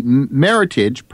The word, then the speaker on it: Meritage: But now you say it's from California, but the Meritage, pronounced like heritage, was selected from more than 6,000 entries in an international contest.